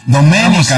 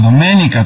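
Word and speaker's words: Doménica: Domenica, Antonio, Domenica, con acento en la E, Antonio.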